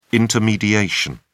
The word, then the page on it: intermediation: British English